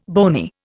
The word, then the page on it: bony: Indian